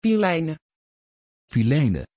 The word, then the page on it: phileine: Dutch